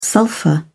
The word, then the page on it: sulphur: British English